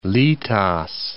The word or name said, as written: litas